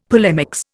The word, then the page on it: polemics: Indian